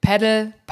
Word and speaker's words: Padel: Also irgendwie, man trifft ganz viele unterschiedliche Aussprachen und ich würde sagen, wir einigen uns auf Padel in dieser Folge, einfach weil es die allgemeingültige und internationale Aussprache ist. Auf Padel kommt man relativ schnell, wenn man sich den Ursprung dieser Sportart mal anschaut, denn die Wurzeln liegen in Mexiko. Mhm. Deswegen ist es diese etwas spanische Aussprache, aber weltweit hat sich Padel durchgesetzt.